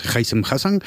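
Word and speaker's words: Haissem Hassan: Y bueno, pues cuando hablabas del Oviedo, el equipo de Hysem Hasan, ya he aprendido a pronunciarlo bien, es Hysem Hasan,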